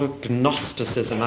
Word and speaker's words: agnosticism: Now, another much bigger difficulty was something called agnosticism. I'm pronouncing the G so you know there is one there.